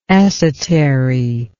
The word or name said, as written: esotery